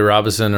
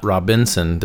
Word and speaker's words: Robison: Charlie Robison, or Robison, possibly, however you want to pronounce his name. It's not Robinson. There's not an extra N in it.